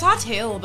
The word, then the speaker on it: Hartill: Yeah, it's Hartill, but you don't really give shit.